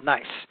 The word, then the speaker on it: gneiss: but they're actually called gneiss. G, and I'll spell it again, G-N-E-I-S-S. And basically, what gneiss is it starts off as a sedimentary rock, which we haven't gotten to yet, which is, say, shale.